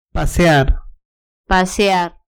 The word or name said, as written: pasear